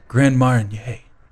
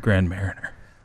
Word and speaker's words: Grand Marnier: Uh, Clay says, Jeff, you really need to work on your French pronunciations, like Grand Marnier, not Grand Mariner.